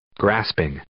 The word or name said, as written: grasping